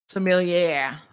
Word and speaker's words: sommelier: Sommelier, I think is how it's pronounced, but hold on. – Sommelier. – Sommelier. – Sommelier. – All right, there you go.